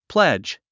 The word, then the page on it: pledge: plédʒ; プレェッジ